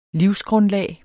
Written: livsgrundlag

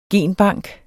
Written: GENBANKEN

